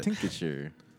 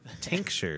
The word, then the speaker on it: tincture: It should be tincture. – We've gone through this. – I know we have, but you just made me think about it. – Tincture. – Tincture. Tincture. Spell it again. T-I-N-C-T-U-R-E. – T-I-N-C-T-U-R-E. – Yeah. – Tincture. – Tincture. – Tincture. Tincture. So when you say it fast, it's tincture.